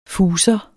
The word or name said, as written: fuser